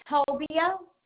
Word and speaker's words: Tobia: Mindy Tobia, T-O-B-I-A.